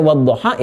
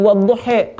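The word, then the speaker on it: wadduha (والضحى): Waras pulang pakai E. Ya, kita baca wadduha itu wadduhe. Antara wadduha dengan wadduhe maknanya sama. Sama waktu maknanya, cuma cara bacanya beda karena keadaan lisan itu berbeda, lebih cocok pakai yang ini.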